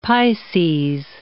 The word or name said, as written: Pisces